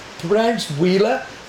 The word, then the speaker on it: Huila: Pronounced Huila, but actually H-U-I-L-A.